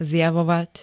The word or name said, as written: zjavovať